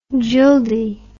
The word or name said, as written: jildi